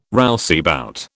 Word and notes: rouseabout: British English

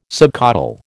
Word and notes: subcaudal: American English

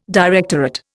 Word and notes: directorate: Indian

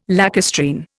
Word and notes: lacustrine: Indian

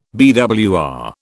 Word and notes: BWR: British English

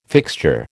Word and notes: fixture: American English

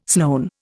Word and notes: Sloane: Indian

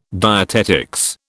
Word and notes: dietetics: British English

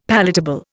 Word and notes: palatable: Indian

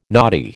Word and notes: knotty: American English

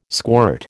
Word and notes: squirt: American English

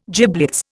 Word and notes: giblets: Indian

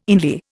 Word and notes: inlier: Indian

